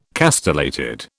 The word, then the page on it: castellated: British English